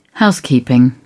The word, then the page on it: housekeeping: British English